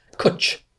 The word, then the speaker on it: cwtch: and it's called cwtch. – Cwtch. This spelling, cwtch. Seriously. – And it's spelled C-W-T-C-H, a very weird spelling collection here.